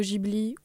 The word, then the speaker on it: Ghibli: Par contre, avec tous les films du studio Ghibli ou Ghibli, en fonction de comment vous le prononcez.